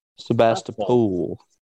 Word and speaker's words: Sebastopol: Sebastopol? Is that how it's said? Sebastopol. Is it? Yes.